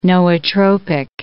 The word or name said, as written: nootropic